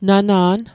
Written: nana'n

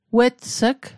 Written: wets'g

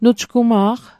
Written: nujgma'q

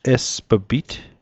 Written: esp'pit

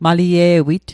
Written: malie'wit

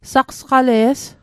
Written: sasqale's

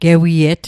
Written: gewiet

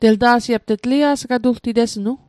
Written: telta'sit

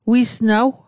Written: wisnaw

